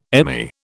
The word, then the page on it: Emmy: British English